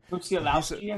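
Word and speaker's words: Musiałowski: And it was Mateusz Musialak, Polish. Okay, I don't know if I'm butchering this name. – Musialowski, I think. Yeah. – Musialowski, okay. Mateusz Musialowski, okay.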